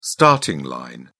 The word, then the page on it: starting line: British English